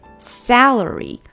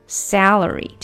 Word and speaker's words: salary: celery 芹菜，注意这个词呢，celery 它跟薪水 salary 非常的接近，一个是 celery，e 的发音 s celery，另外一个是 salary salary，这个是 a 的短音，就是那个梅花音 short a sound salary celery salary 两个是不一样的啊。